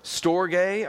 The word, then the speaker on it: storge: You got stergo, storge, I think is how you say it actually, and that's the word for family love in Greek.